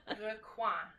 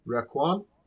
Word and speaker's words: Requin: No, I just got a text from my wife that says, "I know how to pronounce Requin in French. If you want to actually know." Yes, sure. we would love to know. – Okay, go go for it. Requin. Requin. Yeah.